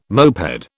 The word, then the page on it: moped: British English